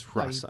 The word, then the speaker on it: Hrasa: The H is not silent. It's Hrasa. I think.